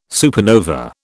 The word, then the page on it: supernova: British English